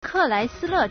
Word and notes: 克莱斯勒: Kèláisīlè

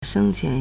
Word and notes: 生前: shēngqián

